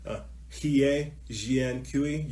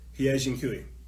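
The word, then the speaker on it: He Jiankui: Hie Jian Kui, you can spell that H-E space J-I-A N-K-U-I. Hie Jian Kui, yeah, go type it in.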